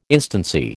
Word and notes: instancy: American English

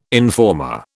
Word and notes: informer: British English

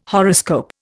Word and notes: horoscope: Indian

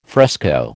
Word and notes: fresco: American English

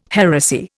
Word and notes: heresy: Indian

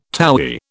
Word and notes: towy: British English

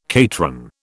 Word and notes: cateran: British English

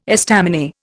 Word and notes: estaminet: Indian

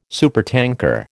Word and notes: supertanker: American English

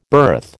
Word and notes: birth: American English

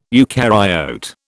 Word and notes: eukaryote: British English

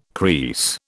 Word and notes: kris: British English

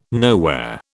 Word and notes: nowhere: British English

